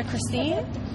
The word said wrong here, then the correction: Chastain: out first from... Yeah, Chastain. – Oh, Chastain, yeah, okay. – Sorry, I said her name wrong.